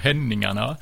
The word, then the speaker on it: Henningarna: Ben, le groupe, c'est Henningarna, ou en suédois, ça sonne un peu plus comme Henningarna.